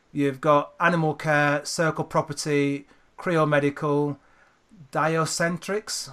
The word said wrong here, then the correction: Diaceutics: Diaceutics, Yeah.